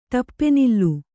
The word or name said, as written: ಟಪ್ಪೆನಿಲ್ಲು